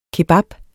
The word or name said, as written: kebab